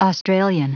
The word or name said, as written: australian